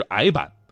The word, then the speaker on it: 呆板: 确凿曾经的读音是确凿，呆板曾经的读音是矮板，一拜天地的拜曾经只读拜，只有这么一个音。